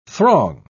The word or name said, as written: throng